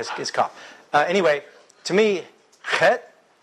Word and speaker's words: Chet: There's really not an English equivalent because it's pronounced chet, chet. Everybody say that, chet. – It's back of the throat, guttural.